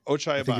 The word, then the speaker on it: Ochai Agbaji: Ochai Agbaji. Ochai Agbaji is how I Ochai Agbaji.